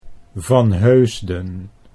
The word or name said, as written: Van Heusden